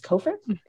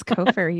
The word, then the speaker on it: Cofer: I always want to say Cofer, but it's Cofer. – It's Cofer. You got it.